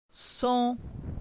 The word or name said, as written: Son